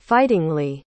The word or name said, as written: fightingly